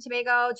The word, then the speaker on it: Tobago: We call it Trinidad and Tobago. There are others who say Tobago, but you know what? Once they get here, it doesn't matter how you pronounce it.